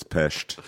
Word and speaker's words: Pest: And if you say it correctly, it's Pest.